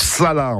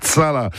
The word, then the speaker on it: chalat: Bref, une précision, en dauphinois, un chalat, ça se prononce sala, en fait, sala.